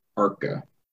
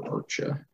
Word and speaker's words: Arce: It's spelled A-R-C-E, but it's pronounced Arca or Archa.